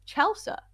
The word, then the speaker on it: Chelsa: It's spelled exactly how it sounds. Like, it would be one thing if I had some long Russian compound name, but it's two syllables. Chelsea. Rhymes with salsa.